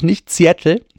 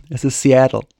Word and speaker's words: Seattle: Es ist nämlich nicht Seattle, es ist Seattle.